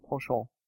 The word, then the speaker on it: penchant: Penchant. That's penchant. Yeah. I can't pronounce it with like an English accent, so I have to do French for some reason.